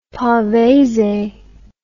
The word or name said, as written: Pavese